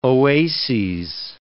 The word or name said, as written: oases